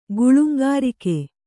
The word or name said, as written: ಗುಳುಂಗಾರಿಕೆ